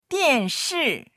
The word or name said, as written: dian4shi4